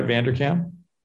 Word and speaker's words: Vanderkam: So you were profiled in Laura Vanderkam's, did I say that right? Vanderkam? – Yeah.